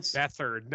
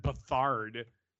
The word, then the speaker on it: Beathard: Beathard came in. – Beathard, not Beathard.